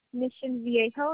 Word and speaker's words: Mission Viejo: Mission Diego? – Mission Viejo, it's spelled V like in Victor, I E J as in Jerry, O.